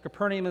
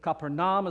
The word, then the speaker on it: Capernaum: Now, it depends upon who you talk to as to how you pronounce that. Capernaum is the common American pronunciation. Capernaum is a little closer. Capernaum is more like how the Hebrew people would say it.